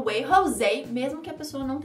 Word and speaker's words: Jose: E Jose é José, mas falado com a pronúncia, eu acho que espanhola, que é só para rimar mesmo, assim, no way e o que rima com way é Jose.